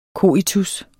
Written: coitus

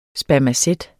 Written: spermacet

